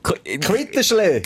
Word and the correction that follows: Quidditch: Quitsch, Indien, so. Quitsch. Quidditch.